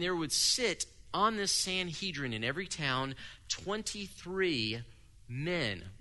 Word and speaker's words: Sanhedrin: In every town, they would establish what was called, and you'll be familiar with this term, a Sanhedrin. Now, one of my dear friends who is far better at language than me, he says, nope, that's not how you say it. It's Sanhedron. Fine.